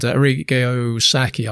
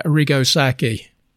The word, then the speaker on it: Arrigo Sacchi: And Arrigo Sacchi, I just mispronounced it there. Arrigo Sacchi, one of the great coaches, a man who changed football forever, I think.